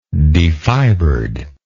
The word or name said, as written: defibered